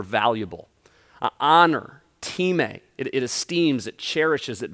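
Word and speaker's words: time (τιμή): Now, the next word I want us to look at is this word, it looks like time, but it's pronounced time in the original Greek, and this is where we draw this word honor from within the pages of Scripture.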